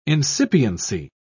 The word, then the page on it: incipiency: British English